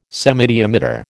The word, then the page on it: semidiameter: American English